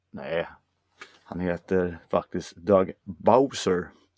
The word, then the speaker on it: Bowser: Nej, han heter Bowser. Aha. Ja, det är skitkult. – Jag vet inte, han kanske stavar det typ... Bauzer eller någonting, men det uttalas Bowser.